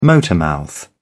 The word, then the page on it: motormouth: British English